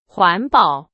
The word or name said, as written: 环保